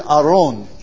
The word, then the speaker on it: Aron: And that's the word Aron, all right? A-R-O-W-N, if we were going to spell it, you know, transliterate it into the English. That's the way it would sound.